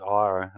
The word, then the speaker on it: Ira: And I kind of considered it just naming it after my name, but you know, when your name's Ira and it's spelled IRA,